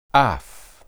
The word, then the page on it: aph: Hebrew